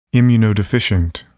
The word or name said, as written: immunodeficient